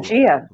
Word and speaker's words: Pangaea: Yes, Pangaea, I think is what it was called, Pangaea. – right? – Yeah. It's not a hard G, right? It's a J sound.